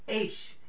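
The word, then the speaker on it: ash (אֵשׁ): The word is pronounced in the Hebrew, ash is how you pronounce that in the Hebrew.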